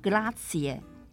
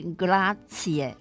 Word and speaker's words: grazie: 一样也是最基本和最实用的单字，就是 grazie。 grazie 拼法是 g r a z i e，grazie，这是谢谢的意思。在意大利文呢，这个 z 哦的发音很像是不卷舌的“ 呲”，所以这整个字念起来是 grazie。